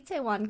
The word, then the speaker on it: Itaewon: Itaewon class? – Pretty, you got it pretty close. Yeah, Itaewon class.